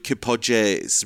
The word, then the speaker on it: Kipchoge: Having a look at the Boston Marathon, people are questioning whether world champion Eliud Kipchoge's reign has come to an end after he placed sixth in the Boston Marathon.